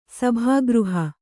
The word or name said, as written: ಸಭಾಗೃಹ